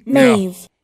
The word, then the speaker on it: Maeve: Maeve.